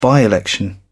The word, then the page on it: by-election: British English